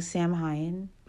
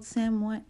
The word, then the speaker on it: Samhain: I was saying Samhain for, you know, the holiday that is Halloween, and she informed me that it's actually called Samhain.